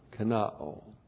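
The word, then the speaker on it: kenoō: K-E-N-O, long O, kenao.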